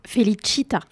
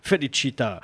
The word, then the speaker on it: Felicita: On accueille Félixita. Félixita, on prononce comme ça ? – Felicita. – Felicita, à l'italienne. – Exactement.